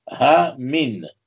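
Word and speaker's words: ha min (המן): Alors Aman, ça s'écrit « He min » He mem nun, et he mem nun, vous pouvez le lire ha min.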